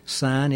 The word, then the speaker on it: syne: But at the same time, you can look at the name, Auld Lang Syne, old meaning old, lang, L-A-N-G, meaning long, syne, S-Y-N-E, is sense. And syne is pronounced like our sign, so keep that in mind.